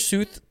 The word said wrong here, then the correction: Seuss: You're pronouncing it wrong. – Dr. Seuss, the popular children's book author's name, is actually pronounced Sois. – Really? – Mm-hmm. – Sois.